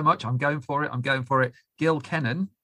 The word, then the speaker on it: Gil Kenan: And that was my conversation with Gil Kenan,